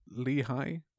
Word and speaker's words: Lehigh: I'm genuinely not certain you've pronounced the name of the university right. – Lehigh? – Yeah, I think that's how I've heard it pronounced before.